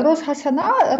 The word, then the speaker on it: Рош Гашана: Так, Рош Гашана. Рош – це означає голова, а Шана – означає рік. Шана наголос падає на останній склад.